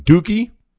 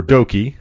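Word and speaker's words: Douki: We got Black Desire, which is Momo Watanabe and Starlight Kid, are teaming with Suzuki-gun Dookie, or Doki, however you want to pronounce it. I call him Dookie, and El Desperado versus Meltier, which is Natsupoi and Tom Nakano, and Suzuki Gun team of Taichi and Yoshinobu Kanemaru.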